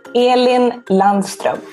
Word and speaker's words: Elin Landström: How do you pronounce your name, for those who don't know how to pronounce it? – Elin Landström. – So that is the correct pronunciation.